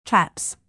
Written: chaps

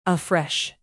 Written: afresh

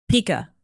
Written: pika

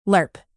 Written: lerp

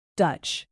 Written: dutch

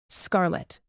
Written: scarlet

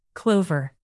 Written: clover

